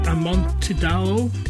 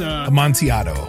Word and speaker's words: Amontillado: Edgar Allan Poe, The Cask of Amontillado. It's Amontillado.